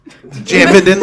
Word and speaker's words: Jividen: Most people just say it as like Jividen. That's how I say it. – Jivin? – Jividen.